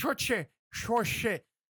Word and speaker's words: Saoirse: May the Schwartz be with Saoirse. I don't know. It's spelled like soiree, but it's pronounced Saoirse.